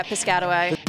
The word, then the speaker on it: Piscataway: Coming up on this episode, we are reporting to you from the Isaac Bonwitz benefit concert in Piscataway. Am I saying that right? – Yeah, Piscataway.